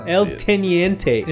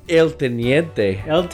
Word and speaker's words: El Teniente: It's called How do you say that? El Teniente. – Or is it that? – El Teniente in the Chilean Andes. Yeah. – El Teniente.